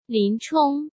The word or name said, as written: Lin Chong